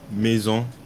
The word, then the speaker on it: Maison: But my first pick will have to be Dreamcatcher Maison. You know, a little French pronunciation for you.